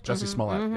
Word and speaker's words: Jussie Smollett: That's another thing that, it's like the, I'm not going to say his name right, Jussie Smollett? – Jussie Smollett, yes.